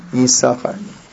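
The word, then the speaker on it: Yisachar (יששכר): But here that you see Yisachar has two sins, and we do not pronounce the second sin. It's a silent sin. Yisachar.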